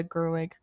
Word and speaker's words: Gerwig: I think that Greta Gerwig, am I saying that right? – Yes.